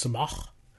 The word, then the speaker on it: sumac: It's you might pronounce it sumac. I believe it's pronounced in the Middle East it's pronounced sumach.